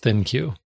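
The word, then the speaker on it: ThinQ: That would be Think with a Q. So maybe I should pronounce it Think Q. I really don't know. – I think it is pronounced Thin Q.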